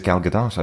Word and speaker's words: Gadot: The movie stars Gal Gadot, Is it not Gal Gadot? – Weirdly, I think she actually pronounces it Gal Gadot. I've been hearing that quite a lot in interviews, so I always pronounce it Gal Gadot, though.